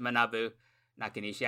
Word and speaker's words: Manabu Nakanishi: We've got the Jurassically immovable human now, Manabu Nakanishi. That was a bad pronunciation, but I'll do what I can. – I usually pronounce it Manabu Nakanishi. I think that was fine.